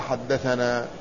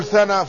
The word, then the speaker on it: حدثنا: لكن عندما ينطقونها ينطقونها حدثنا. ولذلك من الخطأ الذي شاع عندما يقرأ بعض من يقرأ الأسانيد يقول ثنى فلان، وهذا خطأ.